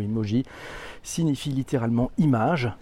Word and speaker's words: emoji: les emojis, à prononcer emoji.